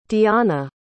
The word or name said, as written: dionna